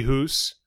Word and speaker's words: Huss: H-U-S-S. – Huss. – Could be Huss. – I'm kidding. I think it's Huss. – Because of the two S's.